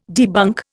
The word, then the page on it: debunk: Indian